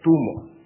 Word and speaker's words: gtummo: El calor de los magos era llamado en idioma tibetano gtummo. En realidad la pronunciación correcta es tumo, tibetano del interior.